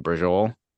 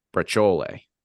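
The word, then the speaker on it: braciole: That is not how that's fucking pronounced. – Is it not brujol? – No. Or it's not how it's supposed to be. That's like fucking gabagool. – Oh, God. – It's either, I think how it should be pronounced is brachole. – Really? – Yeah, like that C should not turn into a J. And that E at the end does fucking matter.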